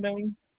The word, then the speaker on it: Boone: Oh, Tracy Boone. – Okay, can you spell that last name for me? – B-O-O-N-E.